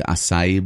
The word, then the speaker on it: acai: Like the acai bowls, yep. You know? – Yep. – Or acai. A-C-A-I.